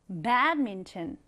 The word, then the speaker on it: badminton: 우리 배드민턴 읽을 때 배드민턴이라고 하지 말고 삼음절로 읽는 거예요. badminton. 한번 더. badminton. 네, 앞으로 이 배드민턴이라는 스포츠는 badminton이라고 읽어주시면 되겠습니다.